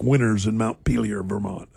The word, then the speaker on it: Montpelier: Montpelier.